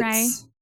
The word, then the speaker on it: Rea: I would have even just said Ray because it's Ray. – like the first syllable of Reagan, like Ronald Reagan.